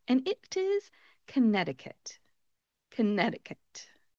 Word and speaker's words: Connecticut: When you look at the spelling, it looks like it should be Connecticut. Connecticut. However, we do not pronounce that middle C in front of the T in Connecticut.